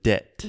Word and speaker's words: debt: So another example of a silent B debt. D E B T. We do not pronounce the B. So you can imagine this word debt, jivida, is just like D E, like an E, E, T, debt.